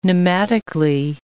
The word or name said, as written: pneumatically